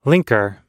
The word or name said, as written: linker